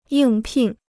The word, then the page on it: 应聘: yìng pìn